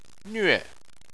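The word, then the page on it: nve4: tone 4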